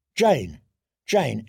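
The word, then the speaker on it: Jeyne: "Jane, Jane, it rhymes with pain."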